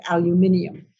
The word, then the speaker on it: aluminium: And I just love to say it the way the British say it, aluminium.